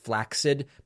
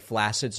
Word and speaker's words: flaccid: And I know that technically the word is pronounced flaccid, but nobody says it that way. So I'm going to continue saying flaccid so people know.